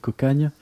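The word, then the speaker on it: Cocanha: Et à ne pas confondre avec l'autre groupe occitanis, Cocagna, qui s'écrit Cocagna, mais qui se dit Cocagne.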